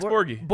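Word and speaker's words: Borghi: It's the Colorado kid, Max Borg. – Oh yeah, Max Borgie. – Borgie, yes. I just didn't know how to say his last name.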